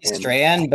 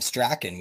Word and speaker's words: Strahan: It's supposed to be Strahan, but I knew a guy named Cam Strachan. We pronounce it Strachan, so I don't know.